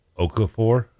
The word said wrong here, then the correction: Okorafor: You just need an extra syllable. Okorafor.